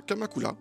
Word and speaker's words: Kamakura: Kamakura. – D'accord. – Oui, Kamakura, c'est le R et le L se prononcent à peu près identiquement en japonais.